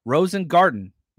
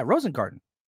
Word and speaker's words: Rosengarten: Rosengarter only if you say the Rookie of the Year movie, man. Is that not right? I think I'm saying it right. Am I saying it wrong? Now, that is kind of my thing, is saying guys' names wrong. I don't do it out of disrespect. I do it out of ignorance. Rosengarten. Yeah, Rosengarten. That's the way it's spelled.